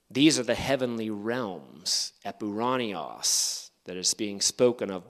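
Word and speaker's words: epouraniois: Now, my plan is to just give an overview of each of these times that Paul employs this word epuranios, which I translated as heavenly realms. I'll spell that for you. It's E-P-O-U-R-A-N-I-O-I-S, epuranios in the transliteration, which I've translated as heavenly realms.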